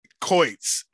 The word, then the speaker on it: Choitz: Choitz.